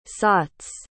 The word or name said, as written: sauts